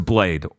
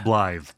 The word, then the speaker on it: Blaidd: Second place goes to Blade or Blythe from... – Blythe, T-H. – T-H? – It's Welsh. – Oh, okay. It's written B-L-A-I-D-D here.